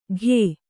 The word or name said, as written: ಘೇ